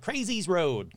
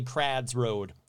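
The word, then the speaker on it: Crwys: Now, it consisted of a workshop and a small yard set back from Criz Road, that's C-R-W-Y-S, Criz Road, in the cafes area of Cardiff. And yes, I know, it's not how you probably pronounce it. You probably pronounce it like Crazy's Road. Come on, Kurt. In Wales, W-Y is pronounced ad. So it's actually Crad's Road. No, I don't know this shit. It's C-R-W-S Road.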